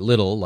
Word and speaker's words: Lidl: The High Court of pronunciation is still out over how to say L-I-D-L in the U.S. The Germans say Lidl like needle. The Brits say little like chicken little.